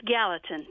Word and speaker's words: Gallatin: It's Gallatin, Tennessee.